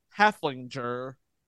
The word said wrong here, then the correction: Heffelfinger: all episode long. It is Heffelfinger Heffelfinger. Heffelfinger. Okay. So now I can say this properly.